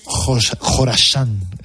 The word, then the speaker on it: Khorasan: Eh, también he pronunciado Khorasan, KH.